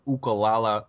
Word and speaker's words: ukulele: And my suggestion this week is a little website called Ukulele Underground, or Ukulala Underground as they pronounce it on the site, because they're actually in Hawaii.